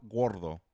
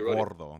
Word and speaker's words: Gordo: El Grande Gordo, that's what it is. But it's funny hearing white people say Gordo all night. – Gordo. – Michael El Grande Gordo. Gordo. You got to roll your R's, brother.